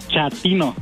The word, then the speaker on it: chatino: Chapino. – ¿Chapino? – No, chatino. – Es como chato, ¿verdad? Chatino. – Sí, asunero, como chato, chatino.